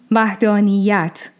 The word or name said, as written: Vaḥdāniyyat